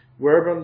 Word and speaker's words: verbum: If you're not familiar much with Latin, these are spelled with V's, so it looks like verbum, but in Latin you pronounce a V kind of like a W sound.